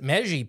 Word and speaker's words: Meiji: Japan's Meiji period. It's M-E-I-J-I. – M-E-I-J-I.